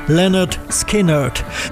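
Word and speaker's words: Lynyrd Skynyrd: Im Titel der Scheibe liefern sie die korrekte Aussprache ihres Namens gleich mit. Pronounced Leonard Skinnerd.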